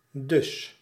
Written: so

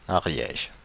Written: Ariège

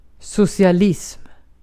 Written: socialism

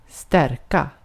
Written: stärka

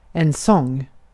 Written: sång

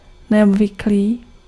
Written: unusual

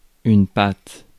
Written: pâte

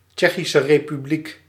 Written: Czech Republic

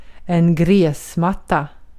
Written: gräsmatta

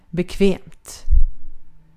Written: bekvämt

